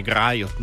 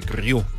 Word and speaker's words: Griot: realtà musicale con i Griot nel blues, i Griot per meglio dire, diciamolo con la pronuncia giusta,